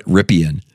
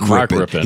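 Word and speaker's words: Rypien: And God, I think I even heard somebody on one of the networks doing the highlights and called him Brett Rippian. I'm like, ooh, God, not only did you forget how to pronounce his name, you forgot that his uncle is Mark Rippon.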